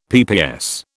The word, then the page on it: PPS: British English